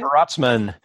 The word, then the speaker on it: Protzman: Perotsman.